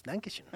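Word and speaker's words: Dankeschön: ¿Así se pronuncia? – Dankenshon, Ajá. Es Dankenshon.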